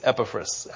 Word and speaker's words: Epaphras: He's important, and I tried to pronounce his name correctly. So I asked the internet how to pronounce his name correctly since he's important. And it wasn't the way I wanted to pronounce it, but it was unanimous. So I'm working on it. It's Epaphras. Epaphras was a Colossian himself.